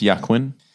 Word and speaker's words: Joaquin: And I like the idea that it's like Joaquin Phoenix. Is it pronounced Yaquin? – Oh, yes. It's a bit late for that, isn't it? – I've been saying Joaquin this whole time.